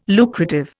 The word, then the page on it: lucrative: Indian